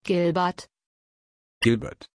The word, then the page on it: Gilbert: German